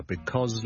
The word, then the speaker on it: because: Ladies and gentlemen, boys and girls, Hi, 跟上一集跟大家讲了 since 和 because 这两个字，那不如顺便讲 because 呢， Because 呢个字个发音，好多时候咧，我哋系唔会读成好完整嘅，我哋正确系 BECAUS，我哋系 because 系 B 啦，第一个 vowel 系 A 啦，第二个 vowel 系 o，跟住后面系一个 z 音 because，但系咧喺口语嘅时候咧，尤其是当呢个字唔系咁紧要嘅时候咧，我哋可能会读得好快好快，大家都可能会见过 COZ 呢个简写，OK。